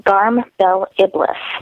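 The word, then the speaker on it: Garm Bel Iblis: I believe it's pronounced Garm Bell Iblis.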